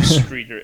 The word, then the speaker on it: Streator: I would be interested in learning the correct pronunciation of Streator, Illinois. I have a feeling was butchering it. – Streator. Mm-hmm. Is that right? – Yeah, I think that's how you read that upside down E thing. Streator.